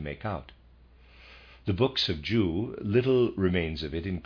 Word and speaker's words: Jeu: and then the text about the secret of Jew, and readers aside, I'm pronouncing J-E-U as Jew, and of readers aside.